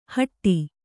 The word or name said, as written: ಹಾಡಿ